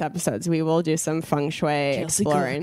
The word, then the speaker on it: feng shui: How do you say it? Is it feng shui? – Feng shui. – Feng shui. – Yes.